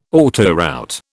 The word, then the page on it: autoroute: British English